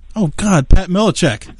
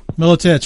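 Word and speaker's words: Miletich: My guest tonight is Pat Milicic. There, I almost, right? Did I say that right? – Milicic, sorry.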